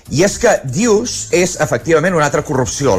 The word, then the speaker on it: deuce: En aquest cas, a la corrupció d'una paraula anglesa que identifica el 40-40, que és, s'escriu D-U-C, com bé deies, D-E-U-C-E, però es pronuncia deuce.